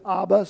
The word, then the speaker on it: Abba: You pronounce it Abba, so we spell it A-B-B-A.